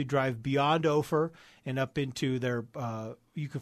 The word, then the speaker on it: Ophir: And that's spelled O-P-H-I-R. – O-P-H-I-R.